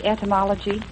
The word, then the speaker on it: etymology: Did I say etymology? – I believe you did. That loses us 1 point there. – Pardon me? – That loses us 1 point. She did pronounce it correct.